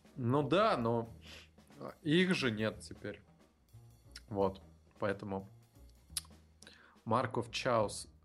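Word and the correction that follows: Chaos: Каос. Как читается? Каос, да. Наверное, Каос. Последнее.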